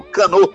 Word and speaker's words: Cano: Fechando então com Dance de la Subvivance, faixa que é do Cano, ou do Cano, como prefere aí na pronúncia do francês.